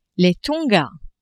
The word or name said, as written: Tonga